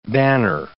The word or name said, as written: banner